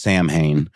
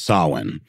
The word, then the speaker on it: Samhain: And if you want to sound smart in front of your friends, or at the very least not sound dumb, it's not pronounced Samhain. It's pronounced Samhain.